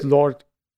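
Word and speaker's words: Lorde: Und diese Low, äh, wie wird das, äh, die Band ausgesprochen? Low, Lore. Ähm, mit diesem Song fand ich irgendwie mega geil. Ich dachte mir. – Ach, du meinst Lord? – Wie? – Meinst du Lord, also die Sängerin?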